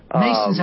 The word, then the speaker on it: Masson: You know, Jeffrey Mason, or Mason. – Mason's how he pronounces it. M-A-S-S-O-N, but it's Jeffrey Mason.